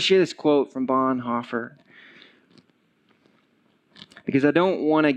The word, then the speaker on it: Bonhoeffer: His name is Dietrich Bonhoeffer. Or Bonhoeffer, as most people like to pronounce it, Dietrich Bonhoeffer.